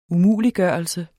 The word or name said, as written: umuliggørelse